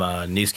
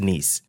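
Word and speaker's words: Niland: I'm from Nice, California. – Nice? – Mm-hmm. – It looks like nice, mm-hmm. – But pronounced niece.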